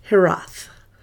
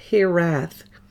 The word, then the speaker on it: hiraeth: but it's called Hirath, or the British call it Hirath. It's spelled H-I-R-A-E-T-H. H-I-R-A-E-T-H.